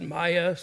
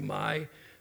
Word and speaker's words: Maya: I pronounce it Maya. Some people say Mai, but it's M-A-Y-A.